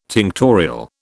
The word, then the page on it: tinctorial: British English